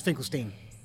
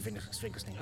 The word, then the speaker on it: Finkelstein: Finkelstein, by the way. But Sorry? – It's Finkelstein. – Same definitely Stein. – You pronounce it Finkelstein. I've had it both ways.